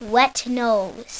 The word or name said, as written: wet nose